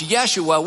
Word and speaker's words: Yeshua: It's where we get the name Joshua, but they didn't have a J, so it's Yeshua. We get Joshua.